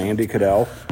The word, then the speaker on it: Andie Caddell: And Andy Cadell.